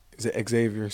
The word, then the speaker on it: Zavier: Growing up, I always knew my name, obviously, was Xavier, spelled with a Z, Z-A-V-I-E-R.